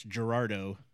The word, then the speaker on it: Gerardo: But his real name was Geraldo. – Yeah, if you don't know how to roll your R's, it's Gerardo, which is just a cool name.